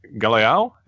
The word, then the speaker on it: Galeão: So from here, we cut to Brazil and the Simpsons exit the Aeroporto Internacional de Galeão.